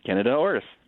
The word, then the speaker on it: Candida auris: I always say it incorrectly, but I think it's Candida auris. – Pretty close. Candida auris. Yes.